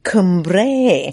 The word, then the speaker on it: Cumbrae: Or should I say Cumbrae, as the locals would have us pronounce it.